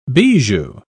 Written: bijou

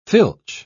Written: filch

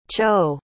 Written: cho

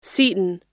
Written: Seton

